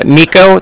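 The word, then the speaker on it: Niko: My name is Nico, that's N-I-K-O.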